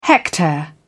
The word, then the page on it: hectare: British English